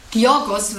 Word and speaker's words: Georgios: Ich weiß nur, Georgos wird es ausgesprochen, der Vorname, weil er Grieche ist.